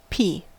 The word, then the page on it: pea: American English